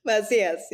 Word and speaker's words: Macias: Macias, yeah.